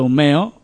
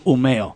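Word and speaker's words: Umeå: Que es, en sí se pronuncia Umeo, por eso el punto de la A encima, se pone como una O pequeñita encima de la A y es Umeo.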